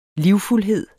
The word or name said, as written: LIVFULDHED